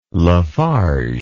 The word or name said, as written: LaFarge